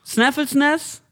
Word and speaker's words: Snæfellsnes: Snæfellsnes. Wenn dieses AE, was so zusammengeschrieben ist, als Ä ausgesprochen wird, es kann auch vielleicht einfach komplett daneben sein.